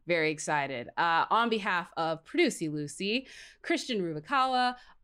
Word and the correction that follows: Ruvalcaba: You got to hit the B where you hit the W. – Yeah. Give it to me, Mark. What was it? – Ruvalcaba.